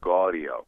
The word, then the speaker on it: Gaudio: Yeah, I mean, it's a little bit of a longer O if you really want to go Italian. It's like Gaudio.